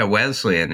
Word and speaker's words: Wesleyan: So you graduated from, I hope I said this right, Wesleyan? – Yeah, Wesleyan in Connecticut, which was undergrad and a pretty activist school.